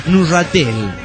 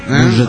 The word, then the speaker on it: Neuchâtel: Dijeron el nombre del cantón donde resido, pero se pronuncia Neuchâtel. – Neuchâtel. – La parte francesa del Nahuachatel es Neuchâtel. En perfecto francés.